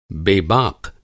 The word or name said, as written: बेबाक़